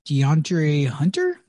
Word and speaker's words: DeAndre Hunter: in DeAndre Hunter.